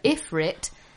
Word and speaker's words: Ifrit: For those who are confused by Bruno's pronunciation, it's Shiva Ifrit and Leviathan.